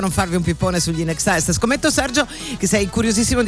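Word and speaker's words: INXS: Allora, il nome della famosa band australiana In Excess si pronuncia appunto come la parola inglese in eccesso, in excess,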